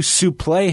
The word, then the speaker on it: suplex: And as Solie said, tried to suplex him back in. – Yes, nice. – Which Gordon Solie, to right till the end, always pronounced it in its proper pronunciation, suplex.